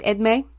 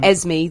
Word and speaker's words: Edme: I think I'm saying that right, Edme. It's E-D-M-E, French name Edme. As long as I don't call him Esme, then it's fine.